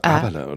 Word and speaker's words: Haberle: Ich würde das H, glaube ich, weglassen. Also Haberle. Äh, Haberle, so.